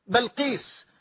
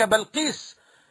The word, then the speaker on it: بلقيس: بل أنا أقول لقد ورث الله سليمان ملك بلقيس، وهذا هو الراجح عندي، أن تنطق الكلمة بفتح الباء لا بكسرها، هذا هو الراجح عندي كما راجعت كلمات وأقوال أهل اللغة.